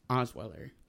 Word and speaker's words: Osweiler: And so Broncos backup quarterback Brock Osweiler.